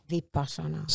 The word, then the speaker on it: Vipassana: Yeah, I always say it wrong. – Vipassana.